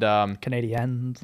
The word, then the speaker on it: Canadiens: And Canadiens. – Yeah, that's how you say it, man. You got to say it like that.